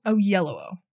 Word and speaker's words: Oyelowo: I picked David Oyelowo, whose name I successfully learned how to pronounce several award seasons ago. – Oyelowo? – Oyelowo.